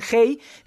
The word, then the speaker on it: Hay: lo pueden ver en Hey se escribe como si fuera I-H-A-Y, Hey Festival,